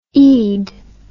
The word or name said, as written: eid